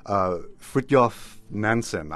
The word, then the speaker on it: Fridtjof Nansen: But there are more obscure figures as well, like Fridtjof Nansen. I'm not sure if I'm pronouncing that correctly. – Yeah. That's right.